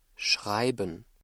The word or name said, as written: schreiben